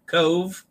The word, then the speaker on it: Cobh: They arrived today in Cove. And it's interesting that Cove is spelled C-O-B-H.